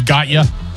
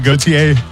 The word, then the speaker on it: Gotye: You know, like it was Gotye. – Yeah. – Instead of Gautier.